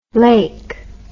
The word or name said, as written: laik